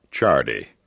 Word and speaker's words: Ciardi: Chardy. It is now an Italian name, and it is spelled C-I-A-R-D-I.